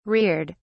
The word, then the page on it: reared: British English